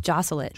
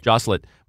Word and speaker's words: Joselit: Joselit. – Joselit.